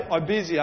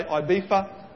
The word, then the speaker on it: Ibiza: There was a prayer team that went to I hear different people pronounce this differently. I say Ibiza. I think the English say Ibiza or something like that. It's an island in Spain.